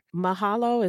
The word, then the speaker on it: mahalo: Mahalo, is that how you pronounce that? – Uh-huh, yeah, thank you. In Hawaiian.